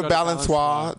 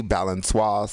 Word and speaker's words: Balançoire: Just go to Balançoire. That's BALANCIORE, Balançoire, spelled like Balancour, 2565 Mission Street.